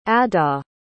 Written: adah

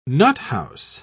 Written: nuthouse

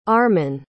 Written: armon

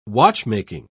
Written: watchmaking

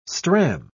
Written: stram

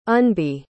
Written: unbe

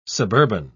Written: suburban

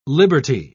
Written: liberty

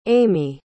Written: amee

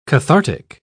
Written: cathartic

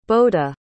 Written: boda

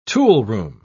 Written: toolroom

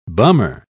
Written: bummer

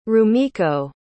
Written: rumiko

